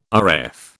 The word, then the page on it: Rf: British English